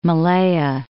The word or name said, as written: Malaya